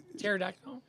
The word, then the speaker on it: pterodactyl: They like pterodactyl? – I did like a pterodactyl. Yeah. I liked, she was like a sneaky bitch because that silent P, and I was like, oh my God, what are you doing?